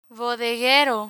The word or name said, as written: bodeguero